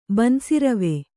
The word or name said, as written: ಬನ್ಸಿರವೆ